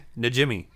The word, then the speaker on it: Najimy: Number five, Kathy Najimy.